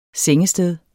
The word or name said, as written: sengested